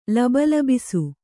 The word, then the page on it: ಲಬಲಬಿಸು: Kannada